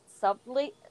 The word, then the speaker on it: subtly: Subtly? Oh my god. – Subtly. Oh, well, I can't pronounce that word either. This is a problem. – Subtly. – Subtly. – The B is silent.